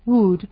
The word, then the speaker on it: would: El cuarto verbo modal es would. Se escribe W-O-U-L-D, pero esta L es muda, entonces would.